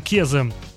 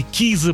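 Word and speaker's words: Киза: И вот, например, отправить на шестое место или с шестого места куда-нибудь повыше песню Кезы, или Кизы, правильно говорить, и Боба Синглера, который называется «I Can't Wait».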